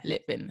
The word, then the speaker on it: Litvin: By Steve Chandler and Rich it's either Litvin or, yeah, Litvin, that's how you say it.